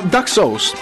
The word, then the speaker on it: Duck Sauce: Che poi si pronuncia Daxus. – Duck Sauce.